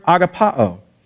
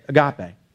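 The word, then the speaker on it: agapao (ἀγαπάω): It's the Greek word agapao, or as Americans say it, agape.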